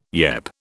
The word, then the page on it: yep: British English